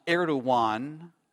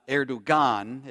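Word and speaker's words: Erdoğan: It is pronounced Erdogan, not Erdogan. It has a G, but in Turkish it has a little dot next to that G, and so you pronounce that as a W.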